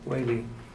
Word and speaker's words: Whaley: We approve the Mangum Welling, excuse me, I don't mean to pronounce that wrong. – Whaley. Whale with a Y on the end. – Whaley.